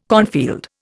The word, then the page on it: cornfield: Indian